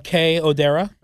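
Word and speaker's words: Kei Odera: Kay Odera. – K-E-I.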